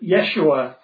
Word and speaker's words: Yeshua: So Jeshua, or, you know, is usually pronounced like a Y, with Yeshua, is a later Hebrew version of the earlier name Joshua, who I'm sure you're familiar with, or Yeshua, or rather Yehoshua, or Yehoshua.